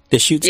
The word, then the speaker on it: Deschutes: Is it Deschutes County? – Deschutes County.